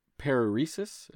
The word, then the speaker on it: paresis: And the thing was a condition known as paresis. Am I saying that right? – That is correct. Paresis.